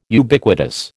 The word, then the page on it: Ubiquitous: American English